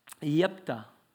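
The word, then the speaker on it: Jephthah: The fourth and final name, Yephthah. It's an interesting pronunciation, but it's actually the way you pronounce it.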